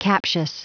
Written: captious

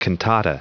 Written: cantata

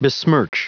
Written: besmirch

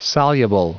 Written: soluble

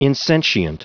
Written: insentient

